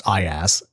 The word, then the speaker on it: IaaS: Because I'm a child, I want to point out that it is pronounced IaaS.